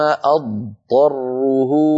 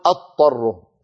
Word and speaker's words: أضطره: أضطره. أرجو أن تلاحظوا الفرق، إن قلنا أضطره أكون قد نطقت طاء مشددة وهذا خطأ، لابد من أنطق ضادا ثم بعد ذلك أنتقل إلى الطاء، فأقول ثم أضطره.